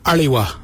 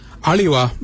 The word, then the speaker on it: Aliwa: It's spelled A-L-I. Now let's add some suffixes with Ali. So if we add W-A, it'd be Aliwa. Aliwa means look out in that direction.